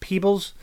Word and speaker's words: Peebles: Peebles? – Yeah.